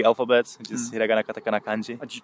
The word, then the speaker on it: Kanji: Kanji, yeah. – Kanji, now I said it right. What did I say? Kanja? Kanja? – Kanja, I don't know.